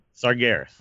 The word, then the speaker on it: Sargeras: The Tomb of Sargeras, however you say it. I've never said it right. – Sargeras. – There you go.